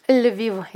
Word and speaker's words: Lviv: Wie spricht man es richtig aus? – Lviv, ja. – Lviv. Le, weich.